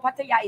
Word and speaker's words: Pattaya: And no, no, but people go, "You're saying Pattaya wrong. You say Pattaya wrong," and I'm like, "It's Pattaya." Pattaya. – Yeah, Pattaya. Yeah. But they, they all they all mess with me saying, said it wrong, said it wrong." – Oh, really? – No, Pattaya is correct.